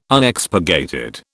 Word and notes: Unexpurgated: British English

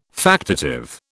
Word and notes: factitive: British English

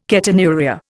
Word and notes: ketonuria: Indian

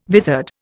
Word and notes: vizard: Indian

